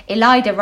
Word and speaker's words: Ilayda: This week, we're joined by actor, comedian, writer and tarot reader, Ilayda Rhymes with Spider, Arden!